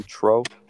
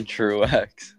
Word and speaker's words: Truex: Is it Martin Trope? – It's Martin Truex.